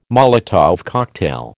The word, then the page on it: Molotov cocktail: American English